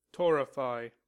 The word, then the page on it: torrefy: American English